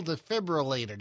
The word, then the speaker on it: defibrillator: There was an AED on site, the automated external defibrillator. Did I say it right? – You did.